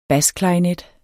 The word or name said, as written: basklarinet